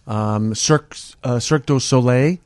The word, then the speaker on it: Cirque du Soleil: We've got comedy, music, Cirque du Soleil.